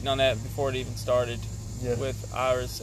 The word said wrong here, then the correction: Idris: Elba, I get the name right. Idris. Idris. Idris. I still can't get it. I said Iris again.